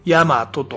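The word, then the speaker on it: 8mato: で、まずこの、ヤマトね、っていうこの施設についてご紹介しますね。八ヶ岳の 8、数字の 8 ね、にアルファベットで MATO って書いて、ヤマトと読むわけでございます。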